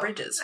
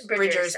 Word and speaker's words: Bridgers: Is it Bridgers or Bridges? – I don't know. I can't tell you. Bridgers? – Oh my God. How dare I mess up her. – Bridgers. Okay. Wow. Butchered her name.